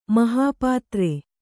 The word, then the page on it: ಮಹಾಪಾತ್ರೆ: Kannada